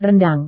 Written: rendang

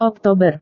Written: oktober

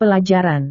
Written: pelajaran

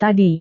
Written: tadi

